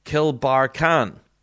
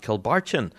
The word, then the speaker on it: Kilbarchan: Kilbarchan is pronounced Kilbarkan. And apparently I've been completely butchering it every time I've said it. Because I've been calling the band Kilbarchan with a CH. But it's Kilbarkan.